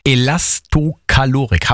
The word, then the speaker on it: Elastokalorik: Es geht um Elastokalorik. Habe ich das jetzt richtig gesagt, Christine Strobel? – Ja, bisschen langsam, aber richtig.